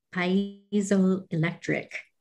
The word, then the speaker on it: piezoelectric: It's able to transduce the energy quite effectively with its, I always mispronounce this word, piezoelectric.